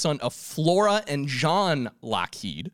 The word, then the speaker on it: Lockheed: So one thing that's interesting to note is that although the name is pronounced Lockheed, it was spelt Lougheed.